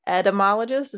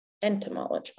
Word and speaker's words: entomologist: Amy is an entomologist. Is that how you say it? – Entomologist. – Entomologist. Okay. Entomologist. That N is like, it's either there or it isn't, and I always forget it.